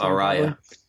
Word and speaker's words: Araya: I don't know how to pronounce it. – Araya. – Shit, I was way off.